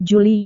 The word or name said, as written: juli